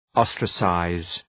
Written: ostracize

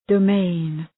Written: domain

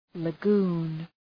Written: lagoon

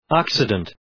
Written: occident

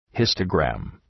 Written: histogram

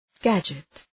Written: gadget

